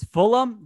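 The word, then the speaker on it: Fulgham: It's Fulham. The GH is silent.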